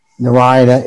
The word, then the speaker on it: Narayana: When we're covering the dimensions of the universe or somewhere around there, it said specifically chanting Narayana in four syllables.